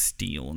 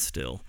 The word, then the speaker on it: Stihl: STIHL is pronounced steel, not still. – Oh, okay. – I tend to pronounce it still because in Oklahoma that's how it's pronounced, but that's not how just to make it clear.